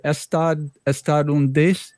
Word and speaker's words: estadunidense: Estadunidense. Tell me how to pronounce that. – Yeah. – In so the intonation is estadunidense. – Estadunidense.